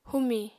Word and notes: humi: apatani

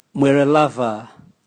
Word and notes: mweralava: Raga

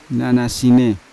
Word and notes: nanasine: Yawa

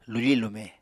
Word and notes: lufi-lume: Apatani

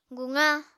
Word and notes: guqa: apatani

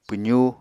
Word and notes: pwzú: Apatani

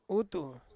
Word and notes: utu: Ansus

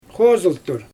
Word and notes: xozɯltur: Xyzyl

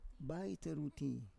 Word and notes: baiteruti: Roon

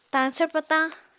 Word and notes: tañsvr patañ: apatani

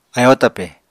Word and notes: ayóo twpè: Apatani

